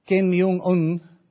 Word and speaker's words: Kim Jong-un: Kim Jong-un. No, I said that right, because I repeated that a few times before I did that.